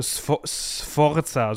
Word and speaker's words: Sforza: und einen Herrn Ascanio Forza. Äh, Sforza, so muss man ihn aussprechen.